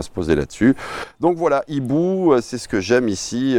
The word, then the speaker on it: Eeboo: Donc Ibu, ça s'écrit E-E-B-O-O en plus.